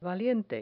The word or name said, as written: valiente